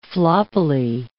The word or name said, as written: floppily